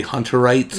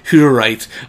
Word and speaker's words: Hutterites: I keep wanting to say Hunterites. – Yeah. – Because it's spelled like H-U. – Right. – But it is Hutterites, walking out of a GNC with a bunch of, like, protein.